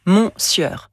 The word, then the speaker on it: monsieur: Oui, à l'écrit, on lit mon sieur. Mais à l'oral, cela donne monsieur. Pourquoi ? Monsieur est un mot proclitique, c'est-à-dire que sa prononciation dépend du mot qui le suit.